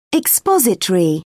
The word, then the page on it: expository: British English